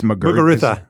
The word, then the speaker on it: Muguruza: It's Muguruza.